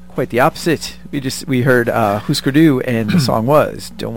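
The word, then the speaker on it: Hüsker Dü: Thank you for pronouncing that correctly. I don't know if that might be. – Oh, Husker Du? – Yeah, Husker Du. Yeah. – I think they were on a list of most commonly mispronounced names. – Which is weird because the two dots are under both you or above both yous. – Yeah, so it's Husker Du.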